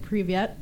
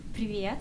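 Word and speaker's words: Privet (привет): I might not be saying this right, but privyet. – Privyet, right here. Privyet. – Privet.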